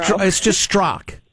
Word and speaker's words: Strzok: It's a T and an R and a Z. It's like three consonants. – Oh, it's just Strzok. The people from that part of the world like their silent letters.